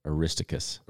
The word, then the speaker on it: Aristarchus: So we got Articus? – Articus? – Yes. – Or? – How do you pronounce that? – I think I had said Aristarchus. – Aristarchus, yeah. That's probably closer.